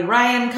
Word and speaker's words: Ryann: You can find me mostly on active on Instagram, which is at Kelly Ryann Co.